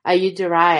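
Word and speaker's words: Ayyadurai: In fact, Dr. Shiva Ayudurai, I believe is how you say his last name, was actually, he actually sent a message saying, please send this to Elon.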